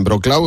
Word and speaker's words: Wrocław: Oye, Pilar, en este rato, ¿tú sabes la cantidad de gente que está dándole a seguir a la cuenta Ayuda en Wrocław? O sea, es una cosa tremenda. Yo pues, no sé si se dice Wrocław, Wrocław... – Es muy difícil de pronunciar. – Se escribe W-R-O-C-L-A-W.